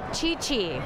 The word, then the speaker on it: Chi-Chi: Chi-Chi. – Got it. – Sounds C-H-I-C-H-I. – Like, spelled like I think it sounds. C-H-I-C-H-I.